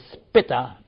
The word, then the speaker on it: Spitta: S-P-I-T-T-A. We would say Spitta, but no, it is not Spitta.